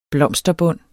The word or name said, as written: blomsterbund